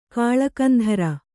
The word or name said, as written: ಕಾಳಕಂಧರ